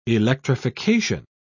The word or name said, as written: electrification